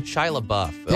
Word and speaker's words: LaBeouf: Shia LaBeouf, although it is a tricky one.